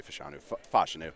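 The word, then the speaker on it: Fashanu: I would say Foshinu. He, to me, is just – he does so much well. What did I say? I feel like I just got his name wrong. Did I just get his name wrong? – No, you didn't.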